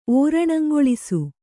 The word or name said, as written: ಓರಣಂಗೊಳಿಸು